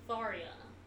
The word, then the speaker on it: Tharja: I thought it was Tharja. – No, well, phonetically, you're supposed to say Tharia.